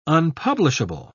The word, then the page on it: unpublishable: British English